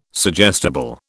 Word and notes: suggestible: British English

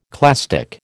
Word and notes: clastic: American English

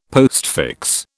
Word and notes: postfix: British English

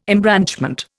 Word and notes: embranchment: Indian

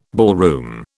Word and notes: ballroom: British English

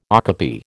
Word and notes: okapi: American English

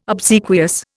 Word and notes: obsequious: Indian

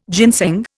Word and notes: ginseng: Indian

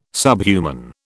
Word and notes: subhuman: British English

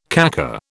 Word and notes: kaka: British English

